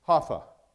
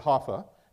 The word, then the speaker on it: Jaffa: In Jaffa, it looks like Joppa, but it's Haffa. That's really how you say it, Haffa.